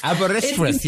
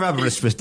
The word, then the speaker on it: Aberystwyth: Aberystwyth. Yes, I am aware of that. I'm aware of Aberystwyth. Don't worry about that. The book is called... – I'll be back with one Aberystwyth.